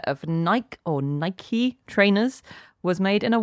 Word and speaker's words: Nike: I've checked the archive and then all we've got after that is Dan saying that Nike is pronounced Nike like Mikey.